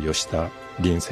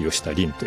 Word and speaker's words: 吉田凜: 登場しているのがこの吉田凜選手です。吉田鈴と書いて吉田凜という面白いですけれども、今回も、本当にもう優勝まであと一歩というところに行きながら、残念ながら 2位になりましたけれども、非常に今注目を集めている素晴らしい選手です。